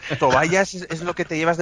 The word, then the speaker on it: Tobias: A mí, perdonadme, pero me gusta la pronunciación en inglés de Tobias, que es Tobayas.